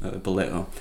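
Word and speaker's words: ballo: Baleo or below, baleo, it's more properly pronounced, baleo, means to throw or to hurl.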